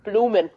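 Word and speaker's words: Bloemen: Ted, I'm not sure how to pronounce this first name, but it's Ted Jan Blomen. – Blomen.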